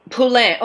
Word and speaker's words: Poulin: We don't really have all the details right now, but his name is Kenneth, and you pronounce his last name Pauline. Paulin, like Paulin. – Poulin.